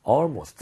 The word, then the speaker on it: almost: almost. – 그렇죠. 지금 almost가 아니라 al. 얘는 얼로 입모양을 벌렸죠.